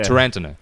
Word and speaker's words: Tarantino: Tarantino has come out in an interview. – Tarantino. – He said it right. – No, yeah. Tarantino.